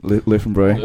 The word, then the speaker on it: Löwenbräu: the Bavarian Beer Cafe or the Lohenbräu. I'm not pronouncing that right. – Löwenbräu.